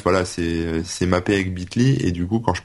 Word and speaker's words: Bitly: Euh, je sais pas comment tu le prononces toi, Patrick, mais oui, oui, c'est Bitly, ouais.